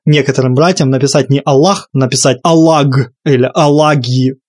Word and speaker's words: Аллах: Ну да, да, оно Аллах, Аллах, да, Х более мягкая. но ты говоришь на русском языке, и в русском языке пишется так, понимаешь, пишется Аллах.